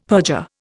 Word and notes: perjure: Indian

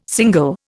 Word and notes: single: Indian

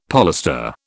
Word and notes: polestar: British English

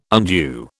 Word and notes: undue: British English

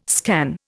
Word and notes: scan: Indian

